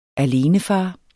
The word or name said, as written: alenefar